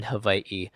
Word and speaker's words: Hawaii: Hawaii, out of respect to the native Hawaiians and the Hawaiian ethnicity, unless they're actually Hawaiian. And yes, the proper way to say it is Hawaii.